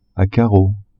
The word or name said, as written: a carreaux